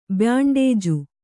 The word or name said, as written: ಬ್ಯಾಂಡೇಜು